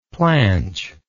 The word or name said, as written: planch